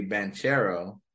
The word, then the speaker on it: Banchero: But Paolo Banchero, I've heard today, is his pronunciation should be Banchero.